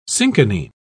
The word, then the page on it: cinchonine: British English